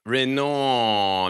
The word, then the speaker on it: Reynaud: Am I saying that right? Renault. I'm trying to get nasal with this. – Yeah, I think you're doing a great job.